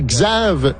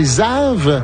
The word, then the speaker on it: Xav: J'allais dire Xav, mais c'est Zav. – Pour Xavier, comme on le prononce en anglais.